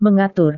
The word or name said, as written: mengatur